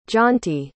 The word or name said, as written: jonty